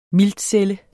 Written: miltcelle

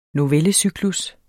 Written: novellecyklus

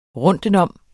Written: rundtenom

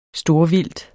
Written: STORVILDTET

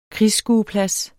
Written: krigsskueplads

